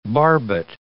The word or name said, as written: barbet